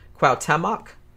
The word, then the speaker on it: Cuauhtémoc: Cuauhtémoc, I think is how you pronounce it.